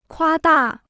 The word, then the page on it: 夸大: kuā dà